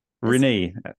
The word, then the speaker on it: Rinne: Rinne. Yes, Rinne.